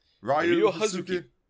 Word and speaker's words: Hazuki: Ryo Hazuki.